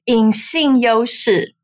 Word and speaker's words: 隐性优势: For our Mandarin listeners, which we actually have many based in Taiwan, the book is called, I'm going to try my best to say, 隐性优势. Am I saying it right? – Yeah, that's great.